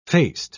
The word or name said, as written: faced